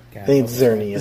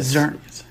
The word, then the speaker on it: Xerneas: So the first card I chose is, I hope I'm saying this right, Xerneas? Xerneas. – Xerneas. – Xerneas.